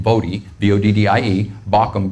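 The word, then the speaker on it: Voddie Baucham: And in his book, Fault Lines, which I highly recommend, Vadi Bakum, or I think you might say his name, Vodi, V-O-D-D-I-E, Bakum, B-A-U-C-H-A-M,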